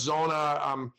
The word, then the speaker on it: Zoona: And I sent an email to a few people and got connected to Brad and Brett, these two brothers starting Zuna, which is pronounced Zona, which sorry. – Means it's real in the local language. Zona. No, no problem. Everybody does. Everybody says it because it's like Z-O-O-N-A.